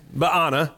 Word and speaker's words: Baana: So it's Baana. That's how you say it.